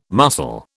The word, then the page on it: mussel: British English